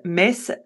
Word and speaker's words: Metz: Et donc, les personnes, en fait, pour montrer la résistance, l'opposition face à l'occupation, eh bien, en fait, les résistants ont commencé à appeler la ville, à prononcer la ville Metz.